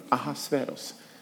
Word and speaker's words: Ahasveros: Det är lite svårt att uttala, tycker jag, men Ahasveros.